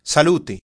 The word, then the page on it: health: /hɛlθ/